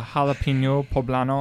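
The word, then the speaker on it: jalapeño poblano: If you want to throw the jalapeno poblano on there.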